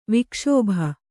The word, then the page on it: ವಿಕ್ಷೋಭ: vikṣōbha